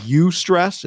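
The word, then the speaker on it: eustress: Most people have heard of this thing called U stress. It's like EU stress is how you spell it.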